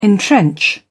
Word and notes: entrench: British English

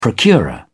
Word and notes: procurer: British English

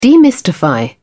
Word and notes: demystify: British English

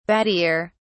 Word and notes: battier: British English

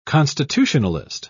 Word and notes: constitutionalist: British English

